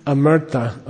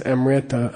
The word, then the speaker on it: amrita: Amrita. Okay. People mispronounce it as amrita. I don't know why. It's amrita.